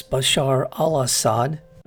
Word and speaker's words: Bashar al-Assad: I believe I said his name incorrectly earlier. It's Bashar al-Assad, who is part of a family business of running Syria.